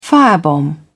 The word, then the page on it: firebomb: British English